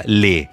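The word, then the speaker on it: lait: Y la leche se escribe L-A-I-T y se pronuncia le.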